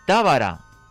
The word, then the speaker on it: Tábara: Pronuncia Tábara en esdrújula.